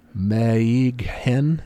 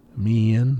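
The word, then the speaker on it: Meighen: But it's like Meehan is how it looks. – Ooh. – Meehan, but the GH kind of does a silent thing, so...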